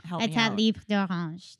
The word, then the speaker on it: Etat Libre d'Orange: I think it started all with I Am Trash from Etat de Libre d'Orange. How do you say that? Yeah. – Etat Libre d'Orange. – Mm-hmm. Perfect.